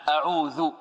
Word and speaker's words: أعوذ: نريد أن نركز على حرف آخر وهو الذال هنا، أعوذ، لأن هناك من ينطق بالذال دالا يقول أعوذ، ولا شك أن أعوذ غير أعود، يعني أعوذ يعني ماذا؟